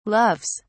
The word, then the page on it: loves: British English